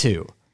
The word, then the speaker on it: cockatoo: Pronouncing cockapoo wrong all the time? – It's cockatoo.